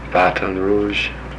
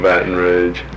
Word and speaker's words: Baton Rouge: Baton Rouge. – The what? Is that your usual pronunciation? – No, Baton Rouge.